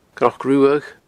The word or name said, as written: cnocruadh